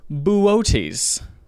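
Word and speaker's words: Boötes: So I looked it up, and it is the constellation B-O-O-T-E-S. Like Booties. – Booties. Booties. – Booties. – Boots. It's actually pronounced Booties.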